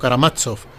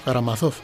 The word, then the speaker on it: Karamazov: Yo lo pronuncio así a, la española, aunque creo que en ruso es algo así como Karamazov. Pero me gusta más decirlo en forma castiza, ¿no? Karamazov.